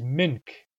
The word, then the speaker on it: mink: There's another word that people use, which is mink, which is a Nuchanoth pronunciation of the English word milk. Mink, pronouncing the L as an N.